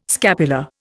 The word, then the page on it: scapular: Indian